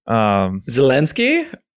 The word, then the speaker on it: Zelensky: They also have a cutout of how do you pronounce his last name, the president, Zelensky. – Yeah, Zelensky.